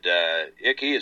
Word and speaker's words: Icke: Icke. Is that how you pronounce David's name? – David Icke? – Icke, yeah, I-C-K-E.